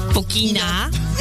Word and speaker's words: Fokina: and he went on to lose to Fokina. – No, say it right. – Fokina, which eliminated him from Hamburg. Oh, that doesn't even sound French. I don't sound French at all.